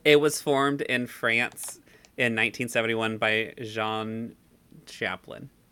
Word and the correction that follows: Chalopin: Chalopin. – Chalopin. That's definitely the French pronunciation.